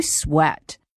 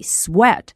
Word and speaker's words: sweat: 就是 how much do you sweat? 那么在这里 sweat, S-W-E-A-T, 这是一个动词，表达的意思是出汗。这里要特别注意一下这个单词的发音是 sweat。 S-W-E-A-T 当中的元音读的是 e，而中国同学在读的时候很容易把它读成另外一个元音 a，念成 sweat。